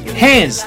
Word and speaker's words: hands: 第二句 hands 结尾的音是浊辅音 z。